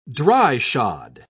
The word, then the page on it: dry-shod: British English